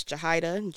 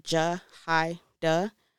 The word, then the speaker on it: Jahaira: It's Jahida, J-A-H-A-I-R-A, pronounced Jahida. I know the R confuses people, but I didn't make it up, so deal with it.